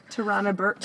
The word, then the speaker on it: Tarana Burke: So, there were, like, widespread sexual allegations against Harvey Weinstein, there's an American social activist, how do you say her name? Burke? – Tarana Burke.